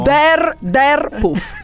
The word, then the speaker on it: Der Puff: Come si pronuncia? Lo diciamo. – Der Puff. – Okay. Der tipo romanesco.